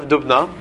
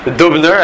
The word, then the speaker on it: Dubna: Dubna? In Hebrew it's spelled with an aleph. Dalet vav beis nun aleph. Dubna. In English sometimes it's printed as Dubno Magid. Dubner. I thought it was Dubner Magid.